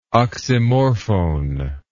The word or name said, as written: oxymorphone